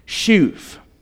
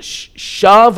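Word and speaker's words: shuv (שוב): It uses the word for repentance that you would pronounce shuv. Or if you were to spell it in English, S-H-U-B, but it's pronounced shove or shuv, depending on who you ask. Shove or shuv, even though it's spelled S-H-U-B in English.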